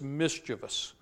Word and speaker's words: mischievous: That word, by the way, is a word that's often mispronounced. A lot of times you hear people pronounce that word mischievous there. It's just a lesson on pronunciation and grammar tonight. I'll throw in at no extra cost there. The word is mischievous.